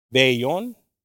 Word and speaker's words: vellón: Velón. El velón. Is velón? Is that right? Anybody? Any Mexicanos here? ¿Cómo? ¿Qué dije yo? Okay, no lo dije bien, hermana. Okay, discúlpame. Vellón. Vellón, okay.